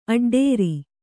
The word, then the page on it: ಆಡಿಟರು: āḍiṭaru